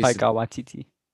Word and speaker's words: Taika Waititi: And then we're going to get a second movie directed by Taika Waititi. I can't pronounce his name. But he's Taika Waititi. – Oh, thank you. Taika Waititi.